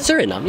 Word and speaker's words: Suriname: And I think it's a beautiful combination of his Dutch roots, but also his origin in Suriname. Is that how I pronounce it? – Suriname, yeah.